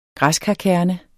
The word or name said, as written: græskarkerne